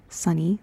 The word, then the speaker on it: Sunny: I'm your host, Sunny. That's spelled S-U-N-N-Y.